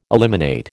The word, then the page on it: eliminate: American English